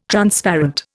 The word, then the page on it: Transparent: Indian